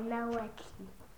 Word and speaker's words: Naoettli: Non, je m'appelle pas Nao. C'est pas fini. Deux T. C'est N-A-O-E-T-T-L-I. – C'est écrit quoi? – Naoetli. – Naoetli. Je m'appelle Naoetli.